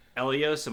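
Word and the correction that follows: Ehlo: Am I saying his name right? Whoever Jordan hit the shot over. – Oh, Elo? – Elo, yeah, sorry.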